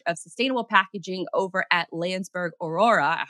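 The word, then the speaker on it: Landsberg Orora: Well, first of all, I want to say that you said it correctly, Landsberg Aurora.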